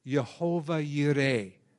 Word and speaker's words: Jehovah Jireh: Jehovah Jireh. That's actually how you say it.